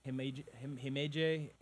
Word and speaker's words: Himeji: Himeji? – Yeah.